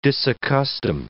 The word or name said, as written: disaccustom